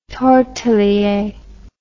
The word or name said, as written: tortelier